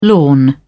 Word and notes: lawn: British English

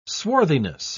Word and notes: swarthiness: British English

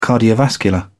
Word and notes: cardiovascular: British English

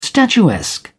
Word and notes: statuesque: British English

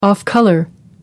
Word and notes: off-colour: American English

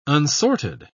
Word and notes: unsorted: British English

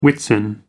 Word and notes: Whitsun: American English

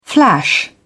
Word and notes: flash back: British English

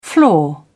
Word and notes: flaw: British English

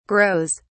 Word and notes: grows: British English

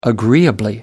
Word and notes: agreeably: British English

